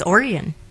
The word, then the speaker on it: Oregon: You see, his pronunciation is just a hide on the R. Our. R. Or. And yours Oregon.